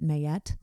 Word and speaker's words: Ma'at: The weight of your soul is measured by your heart, and if your heart is as light as the feather of Ma'at, or some will pronounce it Mayet, mm-hmm.